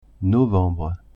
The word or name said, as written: novembre